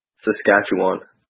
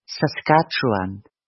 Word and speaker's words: Saskatchewan: Saskatchewan. – Saskatchewan.